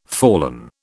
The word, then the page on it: fallen: British English